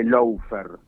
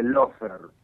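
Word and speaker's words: lawfare: Eh, aclaremos, no se dice Lofer, se dice Lofer, ¿sí? – Lo se pronuncia, se escribe L-A-W y se pronuncia L-O.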